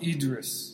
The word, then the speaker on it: Idris: news is on on the news on the internet that I, I totally butcher his name. Sure. Um, Idris. Idris Elba. – Idris. Yeah. Say it again.